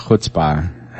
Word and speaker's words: chutzpah: So it's just like, I mean, they have a lot of chutzpah.